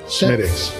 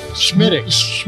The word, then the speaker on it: Smithwick's: Can you pronounce the correct Smithwick's red ale? – Smittix. – Smittix. – Smittix.